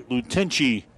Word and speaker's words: Tlatenchi: T-L-A-T-E-N-C-H-I. pronounced Freddie Lutenci for the York Panthers, the number 8 hitter in the batting order here.